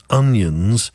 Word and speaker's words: O'Nions: One of my friend's surnames is Onions, but he says it's pronounced O'Nions, as in an Irish name, O apostrophe Nions.